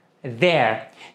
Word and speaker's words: there: There. Se você tem dificuldade em pronunciar essa palavra, basta você prestar atenção nesses dois sons. The. E depois a palavra air. Air. E aí junte as duas. There.